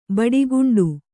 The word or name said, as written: ಬಿಡುಗಂಡಿ